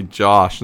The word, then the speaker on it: Josh: Instead of K-Y-L-E, they spell it K-L-Y-E. I'll never get that. – Someone says, what's your name? And I say, Josh. And they say, how you spell that? Yeah. And I'm like, J-O-S-H?